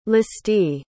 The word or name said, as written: listee